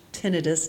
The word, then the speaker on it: tinnitus: So oh, I had some tinnitus, and it is pronounced tinnitus, not tinnitus, but you can pronounce it however you want.